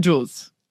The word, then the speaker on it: Jules: Do I pronounce it right? – It's close. It's how do you pronounce it? – Jules. – Jules? – Yeah.